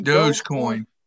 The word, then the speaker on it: Dogecoin: Dogecoin.